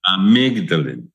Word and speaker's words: amygdalin: What about, let's talk about, and I'm probably going to butcher this word, amygdalin? It's actually vitamin B17, right? – Amygdalin.